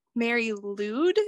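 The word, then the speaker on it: Mari Lwyd: I think it's Mary Lude is how you say it.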